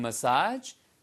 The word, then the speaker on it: massage: 그 다음에 after getting a massage. 지난번에도 말씀드렸죠. 발음이요. 메시지가 아니고요. massage. massage. 액센트가 뒤에 액센트입니다. 뒤에요.